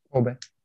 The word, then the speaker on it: Кобе: в місті Кобе Е, наголос, я не знаю, Кобе. – Кобе.